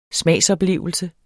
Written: smagsoplevelse

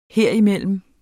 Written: herimellem

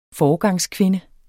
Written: foregangskvinde

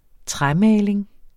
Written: træmaling

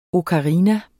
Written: OKARINAER

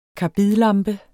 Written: karbidlampe

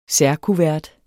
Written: særkuvert

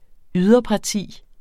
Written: yderparti

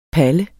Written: Palle

